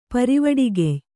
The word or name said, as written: ಪರಿವಿಡಿಗೆಯ್